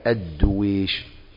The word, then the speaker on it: الدويش: كنت أنا يعني أقول الدويش، لأن الدويش أيضا هناك يعني هذا النطق، تعرفين الأسماء هذه تحتاج إلى تلقي، حتى ضبطها البعض، ضبطوها بضم الدال وكسر الواو وسكون الياء مع الشين، يبقى الدويش.